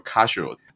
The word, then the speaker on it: Casio: 公司的名字就来自于创立和领导公司的尖尾家族，因为尖尾的日语读音就是 Casio，在英文里面拼出来就是 Casio，就很有日式拼音英语的味道。